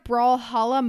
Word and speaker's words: Brawlhalla: Next, we have Brawlhalla Mobile.